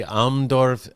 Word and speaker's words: Almdorf: There, uh, if I say it correctly, Almdorf Seinerzeit? Yes, yes, yes, very good.